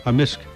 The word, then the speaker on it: amisk: Yeah, so how do you say beaver? – Beaver? – In Cree? – Amisk. – Amisk. – Amisk. – So the K can be silent, but generally it's...